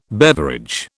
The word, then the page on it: beverage: British English